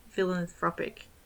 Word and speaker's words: philanthropic: Philanthropist. – No, thropic. It's philanthropic.